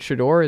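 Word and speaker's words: Shedeur: Like, what if it uh, is it Shador? Is that how you pronounce his son's name? – That's I think that's right.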